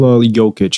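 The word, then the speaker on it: Nikola Jokić: Nikola Jokic.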